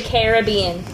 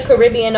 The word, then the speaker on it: Caribbean: This week, we are talking about the 2007 live-action Disney movie based on a ride, Pirates of the Caribbean. – Caribbean. – At World's End. – Caribbean. – I don't say that. I say Pirates of the Caribbean. You visit the Caribbean Ocean, but it's Pirates of the Caribbean.